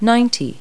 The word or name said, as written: ninety